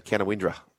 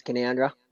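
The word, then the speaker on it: Canowindra: Missile, if you're not from Canoundra, people would see it on the map and they'd say Canawindra. That's what they – is that right? – Yeah, it's spelt Canawindra, but if you know your stuff, it's Canoundra with a bit of Canoundra.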